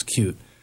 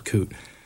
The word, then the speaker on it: Coot: Her name's Coot Grant, after her nickname is Cute. And I guess her next-door neighbor, that was her mom's nickname, and her next-door neighbor used to pronounce it Coot.